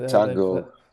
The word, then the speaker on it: Tago: Is it Tungo? Tungo, is that how you? – Yeah, Tungo. Like Tungo, the dance.